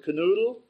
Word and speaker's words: Knödel: If you've ever seen a German menu with Knödelsuppe, it's one big long word, Knödelsuppe, and my name is right there at the beginning, Knödel, but they pronounce it Knödel.